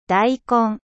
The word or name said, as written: daikon